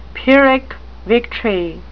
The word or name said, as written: pyrrhic victory